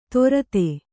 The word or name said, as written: ತೊರತೆ